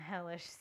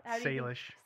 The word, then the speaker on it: Salish: We're doing the severed feet of Salish Sea. – Oh my God, those severed feet. – How do you say it? – Salish. – Salish, okay.